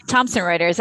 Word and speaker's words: Thomson Reuters: Yeah, Thomson Reuters.